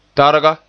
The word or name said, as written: targa